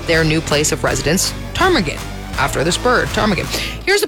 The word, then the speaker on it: ptarmigan: In fact, they had no clue how to even begin spelling the word ptarmigan, which to be fair, makes sense because there's a silent P at the front of that word, which is really stupid.